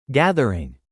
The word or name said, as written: gathering